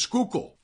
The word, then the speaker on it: Schuylkill: You know, but until they get here and people tell them how to say the proper way to say Schuylkill, which is Schuylkill.